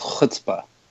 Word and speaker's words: chutzpah: I think you need to roll your R a little bit more. It's chutzpah.